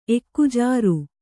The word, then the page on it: ಎಕ್ಕುಜಾರು: Kannada